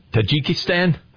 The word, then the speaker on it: Tajikistan: Tajikistan? – Mm-hmm.